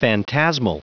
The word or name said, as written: phantasmal